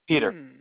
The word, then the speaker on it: Piotr: Okay, and this is, Peter. – and you spell it P-I-O-T-R? – Yeah.